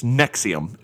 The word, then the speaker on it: NXIVM: And so at first I was didn't know how to pronounce this, but NXIVM, it's Nexium is what they call it.